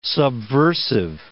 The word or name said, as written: subversive